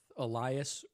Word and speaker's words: Elias: So, for example, I don't want to correct you, but you said my name wrong. It's Elias, like the Spanish way.